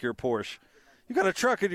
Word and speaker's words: Porsche: It's a Porsche. Secondly, Porsche. I only have one Porsche. It's pronounced Porsche.